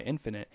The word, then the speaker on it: Infinite: I guess it's Infinite, or it's not Infinity. There's not a Y. Infinite.